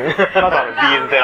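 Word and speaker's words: B'z: うん、例えば、うん、ももクロだったりとか、うん、ももいろフローバーズだったりとか、うん、今の注釈いらないと思うんですけども、うん、あとビーズであったりとか、で、ビーズですけどね。 – え？ビー、 Z って書いて、ビーズ。 – ビーズですけどね。 – そうですか。 – 正しい発音はビーズじゃなくて、ビーズですからね。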